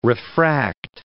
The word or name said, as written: refract